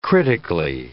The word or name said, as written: critically